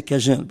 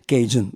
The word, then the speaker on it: Cajun: Lo pronunciamo alla francese Cajun, pronunciamo l'inglese Cajun, vabbè, insomma, io preferisco il francese, visto che musica francofona, il Cajun, lo sai, dico Cajun Fandango.